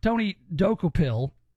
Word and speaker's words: Tony Dokupil: So if you're watching CBS, you hear Tony Dokupil, which is how you say his name.